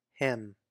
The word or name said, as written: Him